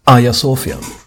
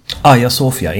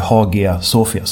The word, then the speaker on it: Hagia Sofia: Hagia Sofia. Hagia Sofia, inte Hagia Sofia som det skrivs. – Ja, men det där har jag också hört. Men jag säger också alltid Hagia Sofia.